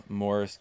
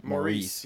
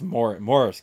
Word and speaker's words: Maurice: because as Americans we pronounce it Maurice. – Yes, it's Morris. It's Morris. That's the British spelling. It's Morris Gibb. – Yes, yes. Even though it looks like Maurice.